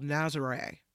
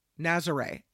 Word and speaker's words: Nazaré: And he goes to this place in Portugal called Nazaré. Nazaré, N-A-Z-A-R-E with an emphasis on the E. Nazaré, Portugal.